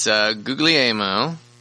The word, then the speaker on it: Guglielmo: The first one I'm going to mispronounce because it's Italian. It's Guglielmo. Guglielmo.